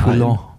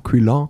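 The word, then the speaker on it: Quillan: Ähm, der Tag danach ähm, war, wenn man sich das Profil angeschaut hat, ich glaube, da war in Quill, wie spricht man das aus? Q-U-I-L-L-A. – Quillon. – Quillon.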